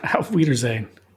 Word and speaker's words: Auf Wiedersehen: And the B-side to this was off Wiedersehen. Did I say that right? – Auf Wiedersehen. – Is it just saying? – Yeah. Auf Wiedersehen. – You sure? – I'm positive.